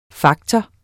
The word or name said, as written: faktor